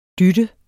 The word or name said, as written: dyt